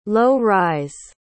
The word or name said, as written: low-rise